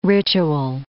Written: ritual

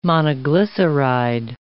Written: monoglyceride

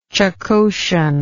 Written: Chukotian

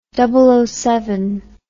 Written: double occupancy

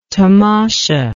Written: tamasha

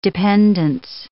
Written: dependance

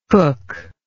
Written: cookless

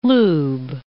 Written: lube

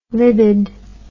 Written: libid

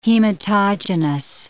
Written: hematogenous

